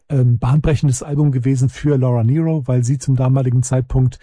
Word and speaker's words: Nyro: Gut, der gute alte Mond hatte gefragt nach Laura Nyro. Ich habe die auch jahrelang Nyro genannt oder sowas, aber Nyro wird sie tatsächlich ausgesprochen.